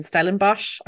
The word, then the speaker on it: Stellenbosch: which is based in Stellenbosch.